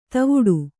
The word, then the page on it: ಧವಡ: dhavḍa